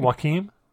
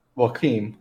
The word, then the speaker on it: Joaquin: Joaquin. – Joaquin.